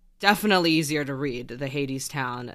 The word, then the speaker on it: Hadestown: And then the text on the bottom, it's either Hadestown or Hadestown. – Hadestown. – Oh, perfect. Okay. Great. Pronounced it right the second time.